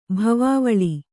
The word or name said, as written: ಭವಾವಳಿ